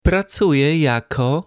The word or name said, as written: pracuje jako